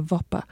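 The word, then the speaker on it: Bhopa: It's B-H-O-P-A, I say a Vopa.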